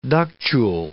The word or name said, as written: ductule